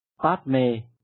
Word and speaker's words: Padme: Padma hay là Padme đều là ý đó, chỉ hơi khác cách phát âm của từng địa phương, hơi sai lạc mà thôi.